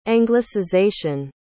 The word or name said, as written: anglicization